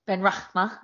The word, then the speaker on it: Benrahma: Benrahma.